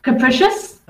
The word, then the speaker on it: capricious: Okay, and the next one on the list is Capricus. Capricus. – Capricious? – Oh, damn, I mispronounced it.